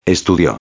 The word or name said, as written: estudio